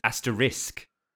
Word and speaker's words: asterisk: So is it asterisk or asterix? Asterix, I would say, but. – Asterix. Yeah, I think it's, but it's asterisk. It's spelt A-S-T-E-R-I-S-K.